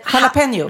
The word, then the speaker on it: jalapeño: Jalapeño. – Jalapeño, exakt. Men många säger jalapeño. Jalapeño. Uh-huh. Och det är fel. För det uttalas, det där j-et hörs ju inte.